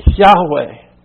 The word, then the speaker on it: Yahweh: In the Hebrew, that's YHWH, pronounced Yahweh.